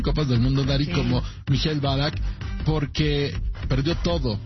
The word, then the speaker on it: Michael Ballack: ¿Michael Ballack? – Michael Ballack, sí.